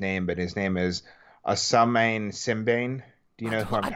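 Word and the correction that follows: Ousmane Sembène: I don't think you pronounced that right. – No, I don't know. – What's the name of the movie? – It's Mandabi. The American translation, the English translation is The Money Order. – Mandabi. – How would you pronounce his name? – Ousmane Simbane.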